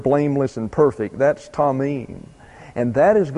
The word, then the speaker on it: tamim: The word for blameless or perfect is tamim. T-A-M-I-M, pronounced tamim. And the word for ways is the plural of an old friend, Derek. D-E-R-E-K.